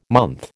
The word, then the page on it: month: American English